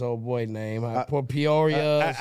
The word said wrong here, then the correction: Pereira: Alex Pereira. – Pereira.